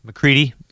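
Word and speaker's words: MacReady: In 1982, this movie came out, obviously, like we mentioned, but, so the McEnready is his name, right? – McCready. – Yeah. Oh God. – McEnready. You're writing your own movie over here. I butchered that.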